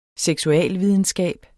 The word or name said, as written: seksualvidenskab